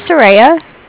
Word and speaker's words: Soraya: This is Soraya. My name is spelled... – Hold on. – Sure. – okay. – It's S-O-R-A-Y-A.